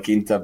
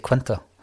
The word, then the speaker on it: Quinta: Quinta, viva la Quinta Brigada. – Did I say Quinta? – Yes.